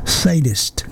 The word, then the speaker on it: sadist: LaDonna says it's sadist, not sadist.